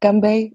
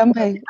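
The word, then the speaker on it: Gambay (干杯): Das heißt einfach Gambay und ich habe es mir für immer gemerkt. Stimmt das schon, ne? – Ja, perfekt. Ausgesprochen. Ja, Gambay.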